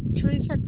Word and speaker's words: Teresa: Teresa Castrejon. – Teresa? – Yes. – T-E-R-E-S-A? – C-A-S-T-R-E-J-O-N.